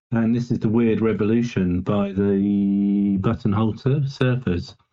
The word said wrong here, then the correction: Butthole Surfers: I think there was a mispronunciation of the word of the name there. – Oh, sorry, Butthole Surfers. – Butthole Surfers. – That's it. Yeah, not Buttonhole. Butthole Surfers.